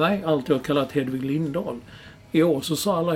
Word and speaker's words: Lindahl: Och enligt vår vän Olof Lund så påstår han att det var hon själv som har sagt att hennes namn ska från och med nu uttalas Lindahl.